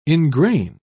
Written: engrain

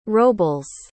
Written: robles

